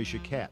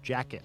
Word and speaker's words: Jacquet: Memories of You, 1945, as played by Illinois Jacquet, or Jacket, or Jacquet, if you like. He's pronounced it all three ways, so take your pick. Jacquet could always play breathy, melodious tenor like that, but his bread and butter was excitable blues in the vein of Flying Home.